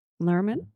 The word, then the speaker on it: Luhrmann: Moulin Rouge soundtrack was directed, produced, and co-written by Baz Luhrmann. – Luhrmann?